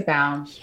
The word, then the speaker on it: Baum: I didn't even check with you how to pronounce your last name. Is that how you say it? – Yeah, Jessica Baum. – Yes. Oh, so you're, I was wrong. – Everyone does that. So I respond to both.